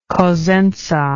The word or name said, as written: cosenza